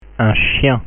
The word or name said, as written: un chien